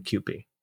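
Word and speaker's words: Kewpie: Oh, did I pronounce it wrong? – QP. Yeah. – I said Q-pie. Sorry, I was wrong.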